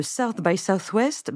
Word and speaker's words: South by Southwest: Oui, et il y a une certaine ironie à ce que ces faillites se produisent maintenant, alors que le festival South by Southwest bat précisément son plein au Texas. Alors, euh, ce festival, ça s'écrit SXSW, c'est une grande foire industrielle de la tech qui dure dix jours.